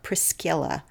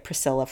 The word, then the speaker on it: Priscilla: And in fact, the C in the name would have been pronounced as a hard K in Latin, so it would have sounded like Priscilla, but I'll just say it Priscilla for the rest of this podcast.